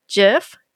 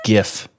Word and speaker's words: GIF: An option to select a monitor for multiple displays or any window, and support for WebM, MP4, GIF, MKV, and it's low on resources. – It's pronounced GIF. No. – GIF, like the peanut GIF. Butter.